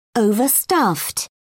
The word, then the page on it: overstuffed: British English